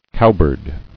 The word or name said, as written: Cowbird